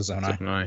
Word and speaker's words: Zonai: Is it Zonai? Is that how it's said in there? – Yeah, the Zonai.